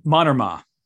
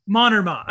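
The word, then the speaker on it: Mannermaa: Tuomo Mannerma. – Mannerma. Okay, that's how to say it.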